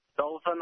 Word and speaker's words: Dothan: Let's go to Dodan, Alabama. Hey, am I saying that right? – Dothan, Alabama.